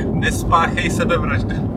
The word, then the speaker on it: Nespáchej sebevraždu: How do you say don't kill yourself? – Nespáchej sebevraždu. – What? – Nespáchej. – Nespáchej. – Sebevraždu. – Sebevraždu. – Okay. – Nespáchej sebevraždu. I don't know. Yeah. There you go. – That was a very good job, by the way. You pronounced it really well.